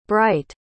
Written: brite

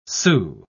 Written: sault

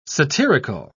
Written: satirical

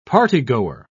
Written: partygoer

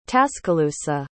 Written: tascalusa

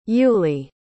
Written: Yuley

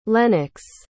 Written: lennox

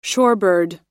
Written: shorebird

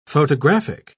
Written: photographic

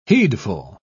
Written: heedful

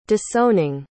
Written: disowning

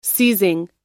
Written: seizing

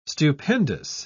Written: stupendous

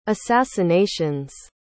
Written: assassinations

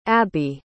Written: Abbi

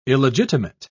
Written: illegitimate

